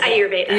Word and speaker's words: Ayurveda: We're going to talk about, I don't even know if I know how to pronounce it, Ayurveda. We're going to talk about... – Yes, Ayurveda.